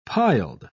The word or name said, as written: piled